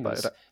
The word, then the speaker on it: Seamus: Seamus.